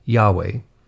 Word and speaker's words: Yahweh: Now, the four consonants in Hebrew used in the word I Am are equivalent to the English letters YHWH, usually pronounced in English as Yahweh.